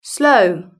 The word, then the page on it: slow: British English